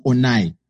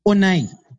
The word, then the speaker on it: Onai: I was like, the pronunciation of my name is not Onai. It's Onai.